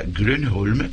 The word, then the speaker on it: Grönholm: El método Grönholm, como lo están pronunciando ustedes, en sueco se pronunciaría Grönholm, pero me imagino que no lo están haciendo.